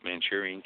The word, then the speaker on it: Manchurian: Manchurian candidate? – Yeah.